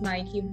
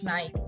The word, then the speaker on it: Nike: The American says it as Nike, the British says Nike.